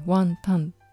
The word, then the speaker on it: 雲呑: で漢字でワンタンって読むんですね。